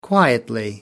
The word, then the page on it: quietly: American English